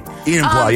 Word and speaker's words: Enumclaw: Jesse in Numquaw. I think that's how you pronounce that. I hope and I pray. – Enumclaw, yes.